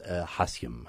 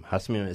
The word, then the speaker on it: hassium: A good example is hassium. Hassium is element number 108. – How do you spell it? – H-A-S-S-I-U-M. – Hassium.